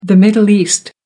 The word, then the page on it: middle east: American English